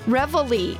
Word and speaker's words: Reveille: Reveille. That could be the way you pronounce it. Reveille. Yeah. Yeah. I put an N in there, didn't I? Renneville. Where the heck did Renneville come from? – It's somewhere in Illinois, I think.